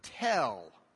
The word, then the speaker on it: tel: That Hebrew word is the word tel. T-E. L-L. Sometimes they spell it with one L.